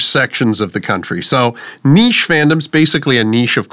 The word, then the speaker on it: niche: The word niche comes from French. That's why that's the way we pronounce it. You will find a few Americans that pronounce it the way Campbell did. I would say niche is the more common pronunciation.